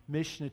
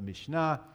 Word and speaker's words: Mishnah: One is the Mishnah, or in Hebrew you actually accent the second syllable, but Mishnah, and that's the compilation of writings, and the other is the Tanaim.